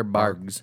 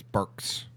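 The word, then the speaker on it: Barq's: Is it Barks or Boggs? – I think it's Barks. – It's a... Does a Q sound like a G? – I think it sounds like a K. – Oh, fuck. – Like bark like a dog.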